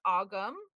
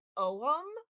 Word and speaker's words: Ogham: So you'll hear it pronounced both ways, but I don't want to just say Ogham and then assume that you guys know what I'm talking about because I always hear it pronounced Ogma or Ogum.